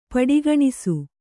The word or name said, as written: ಪಡಿಗಣಿಸು